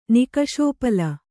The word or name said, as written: ನಿಕಷೋಪಲ